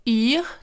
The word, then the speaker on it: Yr: Concernant le climat, je vous recommande de télécharger l'application euh, météo que je vous ai déjà conseillé dans plusieurs épisodes qui s'appelle IR, donc ça s'écrit YR.